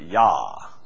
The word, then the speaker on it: Jah: By his name, we would say Jah, but I think they would pronounce it Yah. Anybody know the Hebrew? Is that the J is silent like in Espanol? Yahweh.